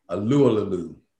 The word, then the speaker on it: Alualu: Bud Dupree is now with the Titans, but they got TJ Watt, Cam Hayward, Stephen Tuitt, and Tyson Alualu. I said it right. Alualu.